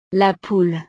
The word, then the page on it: la poule: frances